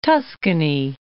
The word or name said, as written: Tuscany